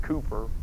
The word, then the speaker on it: Cowper: I have a friend of mine who got a degree in music, who said you pronounce it Cooper, but I don't know, Cowper, Cooper, whatever.